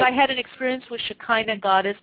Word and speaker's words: Shekinah: Shekinah, it's pronounced Shekinah, it's also Shekinah,